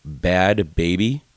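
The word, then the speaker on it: Bhad Bhabie: Her rapping name, because she's got an album, is Bad Baby, but it's spelled B-H-A-D.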